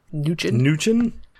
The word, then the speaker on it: Mnuchin: We added, Trump just recently added Steve Mnuchin. I'm going to go with the M is silent.